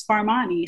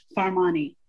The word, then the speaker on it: Farmani: And my last name is Farmani. So I am Mignon Farmani.